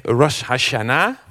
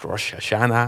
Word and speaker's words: Rosh Hashanah: En het Joodse oud en nieuw, dat heet Rosh Hashanah. Of Rosh Hashanah, dat is maar net waar je de klemtoon legt.